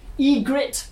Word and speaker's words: Ygritte: Megan has come as, I'm going to say it wrong, Ygritte.